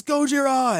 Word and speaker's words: Gojira: one of the Americans in the helicopter says Gojira perfectly. And he's like, it's Gojira! And I'm like, wow, you nailed it. You totally nailed the pronunciation, and it totally came off perfectly.